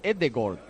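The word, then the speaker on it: Ødegaard: El otro día lo dijo alguien, que la doble A en noruego se pronuncia O, y pensabais que os estaba vacilando, y es verdad. De hecho, Odegaard en noruego es Edegaard.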